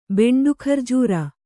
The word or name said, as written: ಬೆಂಡುಖರ್ಜೂರ